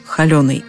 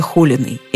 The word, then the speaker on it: холёный: Безусловной нормой признавалось ударение Холиной.